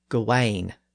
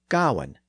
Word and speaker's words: Gawain: In fact, in this particular poem, the poet spells it several different ways, but it's usually spelled G A W A N, implying that the pronunciation was more like Gawain, which is another common pronunciation today.